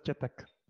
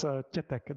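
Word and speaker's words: Tětek: Joseph Tetek. – Tetek. – It's Tetek. There's like a soft E. It's this E with a hook over it.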